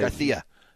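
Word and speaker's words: García: Garcia. – Stop doing that. It's not Barcelona. – That's how they say it. – Barcelona. – It's not Spanish. It's Spanish, but not from Spain. – Oh, okay. He's not from Spain? – No.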